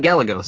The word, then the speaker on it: Gallegos: Like I follow, Randy Gallegos. I think that's how you pronounce his last name. – Gallegos. Yeah, it's not Gallegos, so you're close enough. Gallegos. Close enough. Yeah.